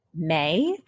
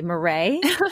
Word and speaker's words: Marae: Taylor May. Am I saying that right? May or Marie? – Marie. – I'm sorry, Marie.